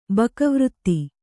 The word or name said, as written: ಬಕವ್ರತಿ